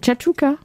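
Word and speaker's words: chakchouka: La fameuse shakushka, moi je sais jamais le dire, c'est très bon, mais difficile à prononcer. – Chachouka, non? – Oui, oui. – C'est chachouka? – Oui, moi j'ai non, c'est ça. Chachouka.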